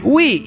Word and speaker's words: Wii: Paz y amor y una carga de sueños. Bueno, esperemos que lo pueda cumplir. – Sí, post-scriptum. El Wii se pronuncia así, Wii, y no Y.